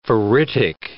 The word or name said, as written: ferritic